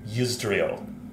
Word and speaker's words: Yusdrayl: I think I was pronouncing it Ysidril, and it's Yusdrayl. I like flipped a couple, um, had a reversal of a couple of the syllables.